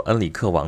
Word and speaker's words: 恩里克: 呃，旅程了，因为葡萄牙有一个非常著名的王子，叫做恩里克王子啊。恩里克王子，恩里克其实就是亨瑞啊。葡萄牙里里边会把它读成恩里克。这个恩里克王子可不一般啊，啊，一直到现在，这个葡萄牙的国旗上面有一片绿，就是为了向啊恩里克王子致敬，等于说他仍然是葡萄牙人的骄傲啊。